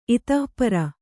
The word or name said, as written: ಇತ್ತಾಪಾರ